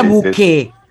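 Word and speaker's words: Bouquet: Ver, yo le diría Buquet, porque viste que la T en francés no se pronuncia.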